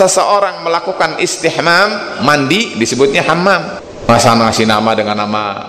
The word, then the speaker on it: Hammam: Hammam adalah seperti itu tulisannya. Tapi saya katakan tadi tidak lumrah biasanya. Ha, Hammam begitu, karena orang Indonesia kalau mengucapkan ha itu nanggung.